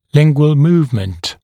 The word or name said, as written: lingual movement